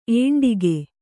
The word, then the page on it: ಏಂಡಿಗೆ: kannada